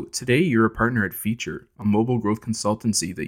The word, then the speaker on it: Phiture: So we just had our 1 year anniversary at Feature, but by the way, it's spelled P-H-I-T-U-R-E. It's a kind of a difficult spelling / pronunciation thing, but yeah, it's like fee with the Greek letter fee.